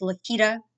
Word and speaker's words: Laketta: which is Lakita Willis. And my first name is spelled L-A-K-E-T-T-A.